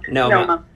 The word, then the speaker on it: Noma: is it Noma or is it Numa? I don't know if I'm saying it right. – Noma.